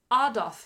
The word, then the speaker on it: Adolf: Apparently, it's Adolf Hitler, not Adolf. Adolf. – Okay. – Yeah. Adolf Hitler. That's probably the proper German pronunciation.